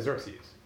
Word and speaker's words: Xerxes: Greek doesn't have a shin, so they have a real problem with Chayarsha. But they do have a Cha, they have Xerxes, but then we pronounce it as Xerxes.